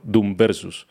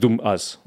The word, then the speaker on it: Doom VS: Se escribe Doom, dos puntos, VS, como versus. Entonces uno pensaría, tendería a pensar que se llama Doom Versus. Pero no, es Doom Us o Dumas.